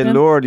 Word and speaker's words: Lourdes: So there's actually, like, the people in France do not pronounce the S at the end, so that's why I keep switching between my pronunciation. – No, go ahead, say Lord, you're the real deal.